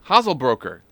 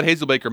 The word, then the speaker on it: Hazelbaker: Hazelbroker. – Hazelbaker.